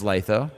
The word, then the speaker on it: Lytho: And we're right outside of one of the breakout sessions that they've had with, is it Litho, L-Y-T-H-O? – It's Litho, right.